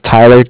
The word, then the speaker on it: Tyler: Okay, my name is Tyler, T-Y-L-E-R, and I am calling from Bank of Cardiff. It's spelled C-A-R-D-I-F-F, as in Frank.